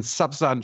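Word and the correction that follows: Sawsan: Sausan, entschuldige, sie spricht sich Sausan aus. – Nee, denn du, sie hat mich im, im Gespräch mehrmals darauf hingewiesen, wie man ihren Namen ausspricht. Safsan.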